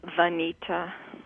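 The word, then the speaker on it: Vanita: Banita. – Banita? – It's like Anita, but it starts with a V.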